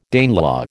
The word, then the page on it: Danelaw: American English